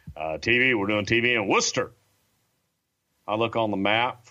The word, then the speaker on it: Worcester: It's spelled W-O-R-C-E-S-T-E-R, Worcester. – There's no H in there.